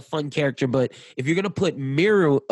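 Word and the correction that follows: Meruem: how do you say his name? – Merum.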